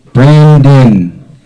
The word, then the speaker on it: Brandyn: B-R-A-N-D-Y-N. Pronounced Brandon. Don't let the Y throw you off.